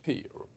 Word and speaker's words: Pier: Som öppnade i november som heter Clarion The Pier. P-I-E-R. Hur uttalas det? – Pier, ska jag säga. Clarion The Pier, Pieren.